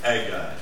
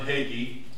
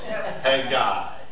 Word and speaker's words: Haggai: And there's many pronunciations of Haggai. But only one is correct. And that's what I just gave you, Haggai. And it's not John Hagee, it's Haggai. A-I, Haggai. Haggai. And if you have problems, go, hey, guy.